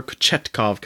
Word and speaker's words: Kochetkov: It's Pyotr Kochetkov comes in, the rookie from Russia, and he played very, very good.